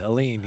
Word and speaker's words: Aline: And you'll find that a video on the Democratic Republic of the Congo, the story of Daniel and Aline. Did I say that correctly? – You? Did. Aline. Yep.